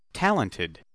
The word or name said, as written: talented